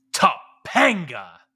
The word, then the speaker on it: Topanga: You didn't say it right. Topanga. You, you got to have that emphasis. Uh, like it is Topanga is one of those special characters, uh, especially for um, our age group.